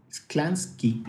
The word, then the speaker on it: Sklansky: se escribe S-K-L-A-N. S-K. Sklansky.